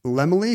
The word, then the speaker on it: Laemmle: It's spelled L-A-E-M-M-L-E. – I've always assumed it's Lemley, because in old Hollywood, they would say, Carl Lemley has a very large family.